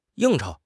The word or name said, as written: 应酬